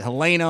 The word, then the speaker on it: Helena: I called it Helena all last week. It turns out it is Helena. It is spelled Helen with just an A at the end.